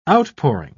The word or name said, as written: outpouring